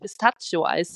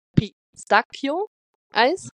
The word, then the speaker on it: pistacchio: Und da bin ich zu meiner italienischen Kollegin gegangen und habe ihr erstmal gesagt, die hat jemand gesagt, ich darf jetzt nicht Pistaccio-Eis sagen. Und ihre Reaktion war, sie hat mit den Augen gerollt und hat gesagt, na meine Güte, das versteht doch jeder. Da musste ich erstmal lachen. Und dann hat sie aber nochmal die richtige Aussprache gemacht. Habe ich es mir jetzt gemerkt? Es war ja Pistacchio-Eis, ne? Und dann meinte sie aber, wenn du sagst Pistazien, sagt man Pistacchi, ne?